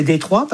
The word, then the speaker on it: Detroit: Oui, alors déjà, c'est une ville que ça devrait se prononcer Détroit, parce que c'est une ville française, en fait, oui.